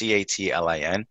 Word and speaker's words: Catlin: Okay, so my last name is Catlin, for those of you that know. So it's Catlin.